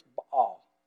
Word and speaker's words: Baal: So we say in English, Baal. Okay, a little side note here. In Hebrew, it's Baal. But it sounds like we're bawling something out of our throat, so we don't say it like that.